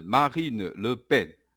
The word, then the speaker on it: Marine Le Pen: 那在我们台湾的媒体上面有谈到说，极右派总统候选人勒鹏或者是雷鹏哈，法文的正确发音是 Marine Le Pen。